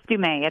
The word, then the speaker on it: Dumais: No, okay. Close. It's Dumais. It's French.